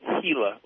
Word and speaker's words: Gila: It's Gila. It's starting, G is pronounced like an H.